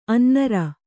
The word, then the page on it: ಅನ್ನರ್: Kannada